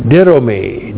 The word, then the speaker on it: didomi: And then we have the verb didomi. D-I-D long O-M-I. Didomi. Didomi means to give.